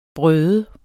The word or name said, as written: brøde